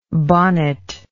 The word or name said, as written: bonnet like